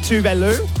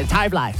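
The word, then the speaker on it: Tove Lo: Technically, you pronounce it Tuvalu. Tuvalu. – Tuvalu. – Yep, but we all say Tavlai.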